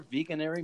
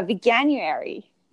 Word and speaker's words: Veganuary: Veganuary month? – Yeah, Veganuary.